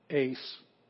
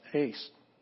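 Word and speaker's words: ace (εἰ): Here's epsilon iota, ace. With a smooth breathing mark, it's pronounced ace.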